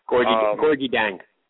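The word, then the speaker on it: Gorgui Dieng: And the guy in the middle, who I can't ever pronounce his name, but kills Syracuse every time. – Gorgui Dang. – There you go, Dang.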